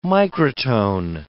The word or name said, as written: microtone